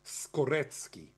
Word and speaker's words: Skorecki: If you're interested in DNA, I would urge you to interview somebody called Karl Skorecki. Karl Skorecki, and he pronounces the C's as ts. It's not like Moroki, which is Mrocki, or it's not like Slaki, which is Slutski. He's a real Skorecki, and he is an expert of that.